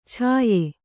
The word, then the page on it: ಛಾಯೆ: chāye